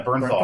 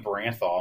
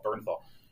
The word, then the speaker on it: Bernthal: Yeah, Bernthal. I was calling like Branthal. I'm like, that's not even correct at all. Bernthal.